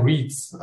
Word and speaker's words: REITs: Ich glaube, die Amerikaner sagen REITs, also REITs.